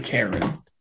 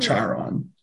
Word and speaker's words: Charon: you pronounce it Karen. – Yeah. – Not Charon.